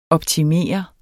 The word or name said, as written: optimere